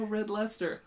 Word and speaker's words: Red Leicester: Oh, Red Lester.